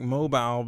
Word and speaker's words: Mobile: I know it's spelled like mobile, but it's like mobile.